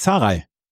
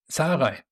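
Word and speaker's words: Zahraj: Du kannst mir helfen, wie er ausgesprochen wird, weil wirklich original von der Challenger Tour hier rübergekommen. Saraj oder wie heißt er? Unser deutscher Spieler. – Patrick Saraj. – Saraj. – Okay. Saraj, ganz, also da musst du gar nicht groß rumdoktern.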